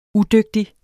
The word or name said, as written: udygtig